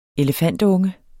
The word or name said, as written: elefantunge